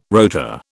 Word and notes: rotor: British English

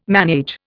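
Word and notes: ménage: Indian

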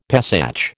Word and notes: Pesach: American English

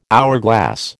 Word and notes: hourglass: American English

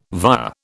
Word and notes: via: British English

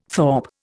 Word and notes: thorp: Indian

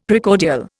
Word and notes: Precordial: Indian

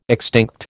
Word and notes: extinct: American English

